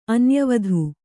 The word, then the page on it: ಅನ್ಯವಧು: Kannada